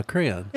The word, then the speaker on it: crayon: One syllable. Crayon.